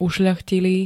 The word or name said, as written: ušľachtilý